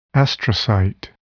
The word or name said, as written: astrocyte